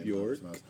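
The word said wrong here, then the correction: Björk: It's pronounced Bjork. – It's kind of horrifying. – Like jerk.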